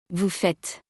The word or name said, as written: vous faites